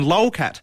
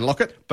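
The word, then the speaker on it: Laukat: Last year, this category was won by the game Near and Far and its designer/artist, our good friend Ryan Lolcat. – Yes. – That's not actually how you pronounce his name. – Ryan Lockett.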